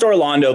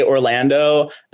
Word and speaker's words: Orlando: It's pronounced Orlando, but I won't get mad at you if you say Orlando unless you've known me for like 5 years and you're still saying my name weird.